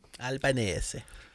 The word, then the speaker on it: Albanese: Uh, men iallfall, han har allerede gått av, og han har blitt erstatta av sosialdemokraten Anthony Albanese, eller Albanese, avhengig av hvordan man vil uttale det. Vi regna jo med han eh, av italiensk eh, herkomst, så kanskje det er Albanese. Albanese.